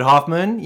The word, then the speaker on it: Hoffman: For David Hoffman, you guys can find me on Instagram at David Hoffman _actor.